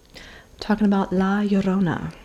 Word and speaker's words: La Llorona: And tell you all about La Llorona. – Yes, we can pronounce it. And Coronas. – We can pronounce it just like our neighbors here down south. La Llorona.